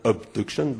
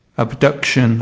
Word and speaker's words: abduction: So you know that it is not abduction, but abduction. – Abduction. You stress the second one.